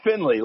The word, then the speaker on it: Finley: It's Finley, like it was with an E.